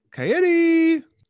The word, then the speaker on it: Cagliari: Cagliari.